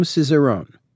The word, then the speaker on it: Cizeron: And Guillaume Cizeron.